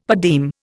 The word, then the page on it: per diem: Indian